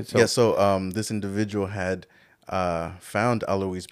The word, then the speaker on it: Alois: You're talking about, how do you pronounce his name? – Alois. – Mm-hmm. Yeah. So the German.